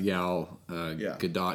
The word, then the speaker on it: Gadot: Let me go back to Gal Gadot. Because I looked it up. And yes, it looks like there is a T in the pronunciation.